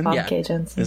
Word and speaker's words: Famke Janssen: Famke Janssen.